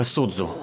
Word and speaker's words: Судзу: Пишется так, как Саотомо Рё, но читается Саотомо Судзу.